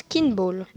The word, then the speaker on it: kinball: Bien sûr, mais comparé à ce qu'on croit, ça se prononce kinball.